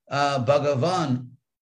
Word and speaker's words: Bhagavan: Now this word Bhagavan, you also have to understand Sanskrit, is not the word Bhagavan with a long A. So this Bhagavan, like fun, Bhagavan, I mean, think of English words fun and fawn, like a fawn is a little deer.